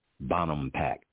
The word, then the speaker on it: Bonampak: This is a, I'm going to bring up a, a site in Mexico and it's spelled uh, B-O-N-A-M-P-A-K. That's Bonampak. Uh, B-O-N, B-O-N, Bonham, A-M, um, yeah, Bottom Pack. P-A-K, Bonampak.